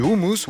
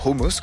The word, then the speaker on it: hummus: C'est le cas du houmous, ou hummus, comme il faut le prononcer.